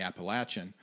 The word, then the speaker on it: Appalachian: but that they made people re-record for saying Appalachian. – Yeah. Like, if you're from West Virginia, you don't say that. – No. Latch, not lay.